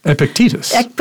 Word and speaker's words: Epictetus: Comes from, I'm going to say it wrong, Epictetus? – Epictetus.